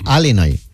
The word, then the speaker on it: Illinois: Se pronuncia Illinois.